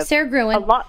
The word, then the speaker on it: Gruen: We rented a cottage in the Scottish Highlands, and I bought, I don't know how to pronounce her last name, Sarah Gruen or Sarah Green. – Sarah Gruen.